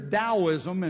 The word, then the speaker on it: Taoism: And then there's Taoism, and I know it says a T there, but that's how you pronounce it.